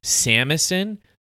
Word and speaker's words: shamisen: Also, we talked about this in our synopsis, or we mentioned it, but the three-stringed instrument that Kubo plays is called a samisen. It's spelled shamisen, but pronounced samisen, and it's a traditional Japanese musical device.